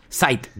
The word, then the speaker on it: Zeit: Oiga, Rammstein va camino a su nuevo disco, que ya lo van a publicar el 29 de abril, se llama Zeit, no sé si lo pronuncié bien, y esta semana se lanzaron una segunda, Zeit, gracias por lo del alemán, yo ni idea de esa joda. – Tampoco sé alemán, pero Zeitgeist es una palabra, ahí está, Zeit.